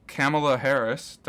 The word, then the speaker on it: Kamala Harris: Kamala Harris. Don't know if I'm pronouncing that right. – So. Yeah, I saw it on the news today. I think you pronounced it right.